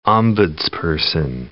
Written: ombudsperson

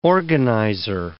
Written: organizer

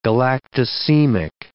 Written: galactosemic

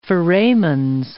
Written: foramens

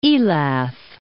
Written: Elath

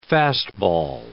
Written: fastball

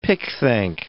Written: pickthank

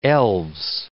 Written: elves

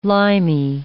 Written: limy

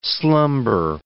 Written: slumber

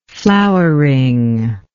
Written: flowering

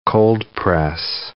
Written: cold-press

